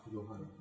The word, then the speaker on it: Johan: And then in Northern Europe, it's pronounced as, it's spelled as J-O-H-A-N. And the J is not pronounced as J, J. It's pronounced as Y. – Johan. – Yeah, so it's Johan.